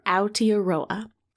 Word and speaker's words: Aotearoa: which, Joe, I 100% pronounced wrong in our Whale Rider episode. So this is my correction. It's okay. Aotearoa.